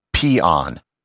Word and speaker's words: pean: He wrote peon, P-E-A-N, which the verb means to sing praise. However, it's pronounced peon.